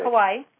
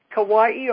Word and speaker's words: Kauai: In, how do you pronounce it? – Kauai. – Kauai, on the island of Kauai.